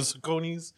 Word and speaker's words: Saucony: So I found out maybe 5-6 years ago that I was pronouncing it wrong the entire time. I used to always say Saucony's. – Yeah, me too. – It's Saucony. – Saucony. – And it even says on the box, Saucony.